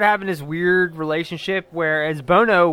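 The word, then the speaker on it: Buono: Angelo Bono is how you pronounce it. – Bono. – I know it's spelled weird and people are like, what, but everything. – I was saying Buno. – Every documentary I watch would just say Bono. It has a U in it.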